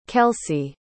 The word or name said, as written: kelsey